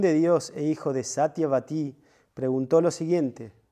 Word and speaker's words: Satyavati: Satyavati. Long I.